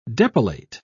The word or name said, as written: depilate